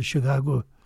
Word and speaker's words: Chicago: Well, the word would be, the Indians would pronounce it Chicago with a sound at the end. – That's kind of how my Southside cousins say it. So they've been right all along, Chicago.